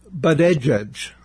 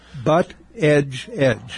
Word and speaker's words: Buttigieg: Pete Buttigieg. – Okay. – Buttigieg.